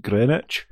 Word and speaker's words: Greenwich: Just an FYI, the W is not pronounced, so it's just Greenwich rather than Greenwich.